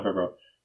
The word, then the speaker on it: Favreau: last Favreau, John Favreau.